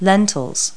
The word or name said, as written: lentils